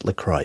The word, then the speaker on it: LaCroix: So when they actually came to us first as a sponsor, and I found out that LaCroix sparkling water, which is pronounced LaCroix, by the way,